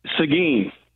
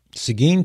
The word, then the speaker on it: Seguin: And is it Seguin, Texas? Is that how you pronounce it? – Seguin. – Seguin, Texas.